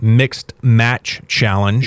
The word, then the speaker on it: Mixed Match Challenge: Alexa Bliss is apparently experiencing numbness in her arm, and that's why she's pulled from the Mixed Max Challenge. Did I say that right? Mixed Match Challenge. – You said it right the second time.